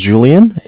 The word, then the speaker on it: Julian: Okay, my name is Julian. It's J-U-L-I-A-N. – Mm-hmm. – And I'm calling from Bank of Cardiff. It's spelled C-A-R, D as in David, mm-hmm.